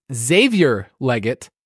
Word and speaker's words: Xavier Legette: This is Xavier Leggett, I believe, is how the X is pronounced.